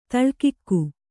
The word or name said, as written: ತಲೆಕೂಗು